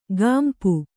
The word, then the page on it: ಗಾಂಪು: gāmpu